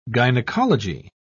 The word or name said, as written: gynecology